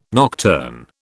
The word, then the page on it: nocturne: British English